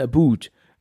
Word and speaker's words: about: Man sagt ja, und das hört man auch ganz selten, also ich höre das eher selten raus, aber die sagen ja nicht about, sondern aboot. Ja, das ist so eins von wenigen Worten, dass die Kanadier wohl anders aussprechen.